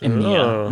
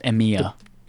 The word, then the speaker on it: EMEA: It's pronounced EMEA. We call it EMEA.